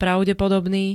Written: pravdepodobný

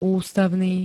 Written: ústavný

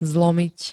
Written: zlomiť

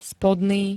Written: spodný

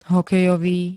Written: hokejový

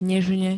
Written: nežne

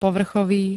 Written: povrchový

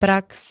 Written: prax

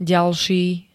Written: ďalší

